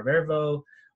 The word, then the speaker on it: Vervo: How did you come up with the idea for Vervo? Is that how you pronounce the company? – It is. Yeah, I'd love to.